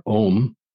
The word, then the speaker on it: Ogham: And that's why if you look into some of the mythology of the Ogham or Om, as it's pronounced,